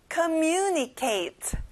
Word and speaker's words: communicate: 소통하다, communicate. 친구들, mu에 강세를 넣어주세요.